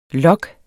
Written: log

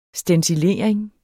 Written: stencilering

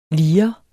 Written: lir

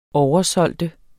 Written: oversælge

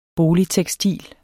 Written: BOLIGTEKSTIL

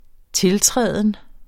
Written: tiltræden

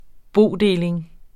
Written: bodeling

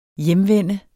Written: hjemvende

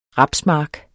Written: rapsmark

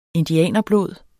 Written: indianerblod

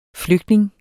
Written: flygtning

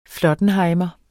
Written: flottenhejmer

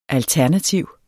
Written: alternativ

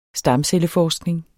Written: stamcelleforskning

